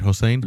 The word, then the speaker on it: Hossein: I think if we say his name right, Hossein. Yep. Hossein.